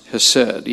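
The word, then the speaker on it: hesed (חסד): It's C-H-E-S-E-D, and I've always heard it pronounced hesed, even though there's a C in front of it. We might say chesed or something along that line.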